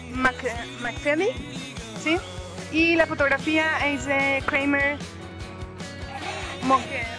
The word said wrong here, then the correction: Morgenthau: A ver, ayúdame, que no lo puedo pronunciar bien. – De Kramer Morgenthau.